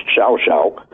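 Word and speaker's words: Llao Llao: It's a place called Shao Shao. – Right. – I don't know how they get that pronunciation, but it's L-L-A-O-L-L-A-O, pronounced Shao Shao.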